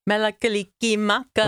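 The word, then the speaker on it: Mele Kalikimaka: Melekalikimaka. – Melekalikimaka.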